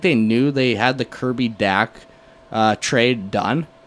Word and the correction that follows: Dach: Is it Dach or Doc? – It's Doc. I just mispronounced it right there. Kirby Doc.